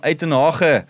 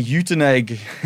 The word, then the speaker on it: Uitenhage: Tian from Uitenhage, or like the English people say, Uitenhage.